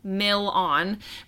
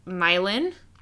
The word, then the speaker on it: Milan: While explaining to Sam about Thomas Edison's birthplace, Dean pronounces the town of Milan, Ohio, as Mill-on, but the actual pronunciation is Mylan.